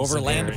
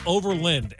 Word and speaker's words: Overland: I think they should spell it out, Overland, L-I-N-D, just so people get the pronunciation right.